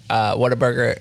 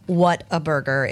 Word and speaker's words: Whataburger: I also love that you're pronouncing it correctly. I lived in Texas for a hot stint. I have some family from Texas, and it isn't Whataburger. It's Whataburger.